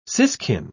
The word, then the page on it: siskin: British English